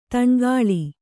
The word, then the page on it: ದನುಕುಲ: Kannada